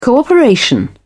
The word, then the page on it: cooperation: British English